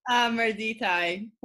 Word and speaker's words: Mirditai: Mirtai. Did I say it right? – No. – No. See? Rose, say your last name for the group. – Mirditai. – Mirditai. I left out a whole syllable there.